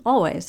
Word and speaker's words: Alvvays: And just so you know, if you've never heard of them also, it's spelled A-L-V-V-A-Y-S. So, you know, you put those two V's together, it looks like a W, and they pronounce their name Always, so it works.